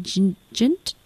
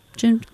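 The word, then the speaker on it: JNT: No idea how you pronounce it. It's J-N-T, Jint, Jint. It's from Maribu State in collaboration with Pedestrian.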